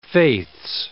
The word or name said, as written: faiths